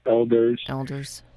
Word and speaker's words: Oelders: and I think it's actually pronounced O-E-L-D-E-R-S, Elders.